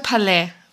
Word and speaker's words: Palais: Ich habe gesagt Palais. Ich habe es französisch ausgesprochen. Ach so, ah, Palais. Du, nee, ah, ja, Palais. ja, du hast recht, ja, genau.